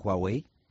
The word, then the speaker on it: Huawei: Se has invitado al estudio a Javier de la Asunción de Huawei.